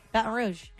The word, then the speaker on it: Baton Rouge: How do you say it really? Baton Rouge? – Baton Rouge.